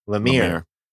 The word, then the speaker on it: Lemire: Lemire.